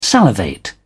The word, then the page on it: salivate: British English